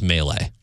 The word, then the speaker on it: Meile: He was promoting his vodka, which is spelled M-E-I-L-E. He said it's pronounced Melee.